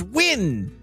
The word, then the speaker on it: Nguyen: Like in China, there's like N-G-U-Y-E-N. That's pronounced Win.